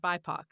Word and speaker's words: BIPOC: We're using the term BIPOC now, and we pronounce it BIPOC.